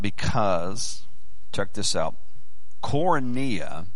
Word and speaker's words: koinonia: But the word is koinonia. Koinonia. Let me read it to you and spell it for you. That's K-O-I. K-O-I-N-O-N-I-A. Koinonia. See if I got that spelled right.